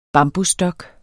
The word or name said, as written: bambusstok